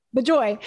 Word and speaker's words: Bijoy: I was at a venture capitalist conference and Renji, I don't know how to say your last name, I never asked. – Bijoy.